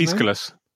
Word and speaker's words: Aeschylus: And then we've had a few other emails that said that, and also mentioned that the acclaimed Greek tragedarian Aeschylus, is that how you say it? – Aeschylus.